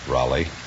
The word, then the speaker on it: Roly: Oh, Roly pronounce it Raleigh? Yes, sir.